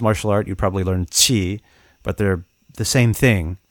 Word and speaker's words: qi: And in Chinese, particularly Mandarin Chinese, it's pronounced, I'm sorry, I don't have the tones, but qi. It sounds a lot like ch, but it's like, it's like a different part of the tongue that hits the palate. But anyway, that's the sound in Chinese.